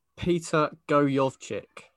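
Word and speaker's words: Peter Gojowczyk: Peter Goyovchik.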